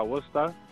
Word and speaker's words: Worcester: En Worcester, la ciudad se llama Worcester, que se pronuncia Worcester,